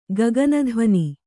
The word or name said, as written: ಗಗನಧ್ವನಿ